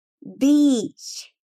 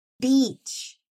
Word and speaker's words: beach: Beach significa praia e é o som alongado. Pensa num dia de sol, sem nuvens, um vento fresquinho, um mar com a temperatura perfeita. Deu um sorrisinho no rosto? Então você já sabe como pronunciar, né? Beach. Beach.